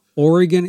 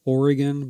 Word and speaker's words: Oregon: Oregon is spelled and said as Oregon by native speakers, not Oregon, as a old sticker used to have on the back of trucks to pronounce it.